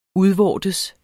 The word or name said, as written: udvortes